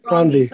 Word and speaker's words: Ronvi: Ron V. – Yeah. Yes. Ron V. I said it right.